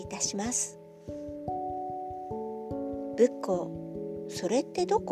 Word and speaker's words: 仏向: 正解は仏に向かうと書いて仏教と読みます